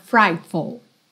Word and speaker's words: frightful: Frightful. E atenção a esse sufixo F-U-L que você não tem full, tá? É full, full, frightful, frightful. A sílaba tônica tá no fright, tá?